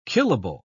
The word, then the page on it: killable: British English